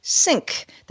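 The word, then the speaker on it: Cync: under the name Sync. That's spelled with a C, C-Y-N-C.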